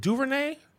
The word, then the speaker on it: DuVernay: Ava, is it DuVernay? – Yeah, you said it right.